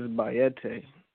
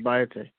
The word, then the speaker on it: Bayete: My first name is Bayete. It's pronounced like the Spanish word Cayete, but it's Bayete. – Bayete, B-A-Y-A-T-A.